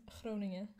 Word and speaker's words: Groningen: No, Maastricht and Groningen.